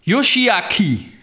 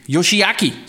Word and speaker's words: Yoshiaki: Jo, Yoshiaki, Yoshiaki, sådan må det være.